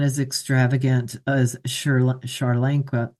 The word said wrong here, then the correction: Sri Lanka: Did I say that right? Sri Lanka.